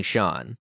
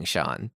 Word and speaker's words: Sian: I mean, it's spelled S-I-A-N, I think. But yeah, I'm pretty sure they were just saying Sean.